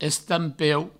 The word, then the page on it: estampeu: Provençal